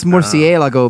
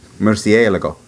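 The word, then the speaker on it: Murciélago: Well, you can tell it's Murcielago uh, because there's literally an accent on the e.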